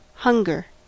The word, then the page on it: hunger: American English